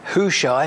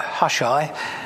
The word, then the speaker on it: Hushai: And I'm told it's pronounced Hushai, not Hushai, as I mentioned last week, because I met someone by that name only last week.